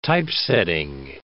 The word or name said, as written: typesetting